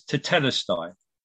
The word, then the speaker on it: tetelestai: I'm not a clever Greek reader by any means, so I had to Google it to see how do you actually pronounce that. It's tetelestai, and it just means it is complete.